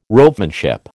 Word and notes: ropemanship: American English

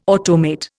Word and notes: automate: Indian

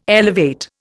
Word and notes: elevate: Indian

